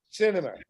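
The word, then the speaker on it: Sinema: how do you pronounce the Arizona lady? – Cinema, like going to the movie.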